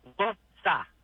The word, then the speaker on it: Worcester: Yeah, two syllables. Worcester.